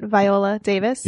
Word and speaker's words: Viola Davis: Viola Davis? – Yeah.